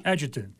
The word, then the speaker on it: adjutant: We're joined by a special guest, who is the adjutant of... Did I pronounce that correctly? – Adjutant. That's correct.